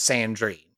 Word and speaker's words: Sandrine: I believe it's Sandrine. That's how I would say it, unless that, you feel that's how like... I would certainly say it that way, yes.